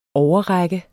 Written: OVERRAKTES

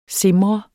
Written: SIMR

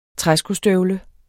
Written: træskostøvle

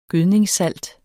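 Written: GØDNINGSSALT

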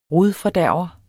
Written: rodfordærver

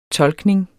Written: tolkning

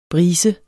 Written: brise

